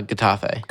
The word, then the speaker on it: Getafe: Gosh, that seems like a long time ago, the Valencia match, because today was, do you say Getafe or Getafe? – No, Getafe. – Getafe? Hard G? Because that's not what, who's the one who's not Ray?